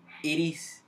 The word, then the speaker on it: it is: Así como yo lo digo por palabra it is, lo puedes unir para que la pronunciación sea más fluida. Entonces vas a decir iris, como que si la R es, perdón, como que si la T fuese una R. Entonces dices iris.